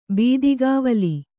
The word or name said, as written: ಬೀದಿಗಾವಲಿ